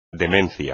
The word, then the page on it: demencia: espagnol